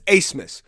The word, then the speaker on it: Abmas: So we got Alex Asemus which if you're wondering how that's spelled, it's A B. Yes, you heard that correctly. A B M A S, but it's Asmus. Nobody knows what's going on. They don't even know. They just know that's how you say it, all right?